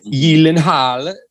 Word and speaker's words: Gyllenhaal: Gyllenhaal, though, however, um, I learned that from Conan.